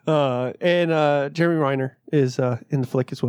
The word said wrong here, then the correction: Renner: He still said it wrong. It's Jeremy Renner. – Renner? I always heard Reiner. Sorry. Renner? Jeremy Renner.